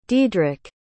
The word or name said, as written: dedrick